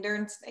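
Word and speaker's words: Dürnstein: So the next day you were in Dernstein. Is that how you pronounce it? – Yes. – Okay. In Dernstein.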